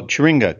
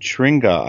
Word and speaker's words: churinga: Okay, so Turinga came out of that, and that's spelled C-H-U-R-I-N-G-A. And Turinga, I think I looked it up.